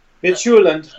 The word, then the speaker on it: Midtjylland: Midtjylland. Okay, okay. Uh, how do you say it? – Midtjylland.